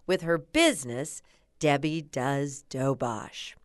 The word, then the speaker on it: Doberge: Citing the city's love for everything French, Ledner named her version Doberge, D O B E R G E a new word New Orleanians have been pronouncing in fancy, pretentious ways ever since. But us old timers, including Charlotte McGehee, still say dobosh.